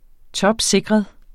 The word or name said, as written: topsikret